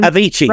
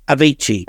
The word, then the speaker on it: Avicii: Avicii. – Right, but was it? – Avicii.